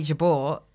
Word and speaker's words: Jabour: it's Bridie Jabour, and thought it was, I think, a little bit funny to use the original, or I was just testing it out using the actual pronunciation.